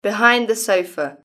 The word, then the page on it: behind the sofa: British English